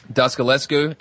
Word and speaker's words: Dascalescu: Dascalescu.